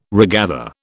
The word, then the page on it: regather: British English